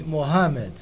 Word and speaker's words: Mohammed: Aqui adotarei o nome Mohammed, que acompanha a pronúncia árabe original.